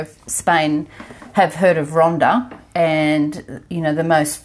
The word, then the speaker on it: Ronda: To Ronda, spelt without a H. Okay. – Yeah, Ronda.